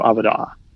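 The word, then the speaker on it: Avodah: And that word is Avadah, or if you pronounce it probably correctly in Hebrew, Avadah.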